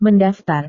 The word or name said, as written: mendaftar